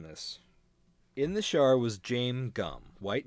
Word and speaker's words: Jame: Okay, well, my note is that it's interesting that Harris wrote Jame Gum insists on being called Jame with a silent S, despite his name being spelled James.